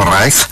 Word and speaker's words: Reich: Sí, pero eso no es para la gente como usted lo pronunció correctamente en alemán, dijo Reich.